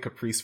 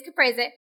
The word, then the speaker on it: caprese: A caprese panini. – Is that how you actually say that word? – Yes. – Oh, okay. I've been calling it caprese for like... – Yep, ever. Nope. It's caprese.